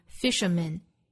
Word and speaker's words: fisherman: 下面 ,a lonely fisherman afloat, fisherman 后面的 man, 注意字母 a 是弱读的 ,a 的发音 ,fisherman, 后面呢是 afloat, 和最后一句的 boat, 又是押韵的 ,boat afloat, 最后一句 is fishing snow in lonely boat.